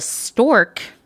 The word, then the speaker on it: Storque: It had its own magazine video cast called The Stork, that's spelled S-T-O-R-Q-U-E, in case you were wondering, which the New York Times called a DIY business school.